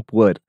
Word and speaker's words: Pu'er: I will spell it first before I attempt to pronounce it. P-U-E-R Hong Yin Yuan Cha. – So puer. – Puer.